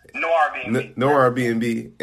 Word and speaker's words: Noirbnb: He's the co-founder of Noir Airbnb. I'm saying that right, correct? – Noir Airbnb.